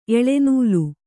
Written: ಎಳೆನೂಲು

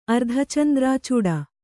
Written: ಅರ್ಧಚಂದ್ರಾಚೂಡ

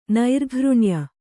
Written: ನೈರ್ಘೃಣ್ಯ

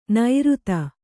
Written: ನೈರುತ

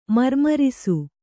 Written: ಮಾರ್ಮೊರಸು

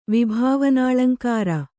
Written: ವಿಭಾವನಾಳಂಕಾರ

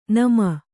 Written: ನೆಮ್ಮಿ

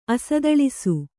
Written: ಅಸದಳಿಸು

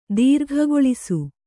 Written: ದೀರ್ಘಗೊಳಿಸು

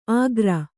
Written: ಆಗ್ರ

